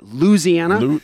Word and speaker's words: Louisiana: I still didn't say it right. Louisiana. – Just like you're losing something.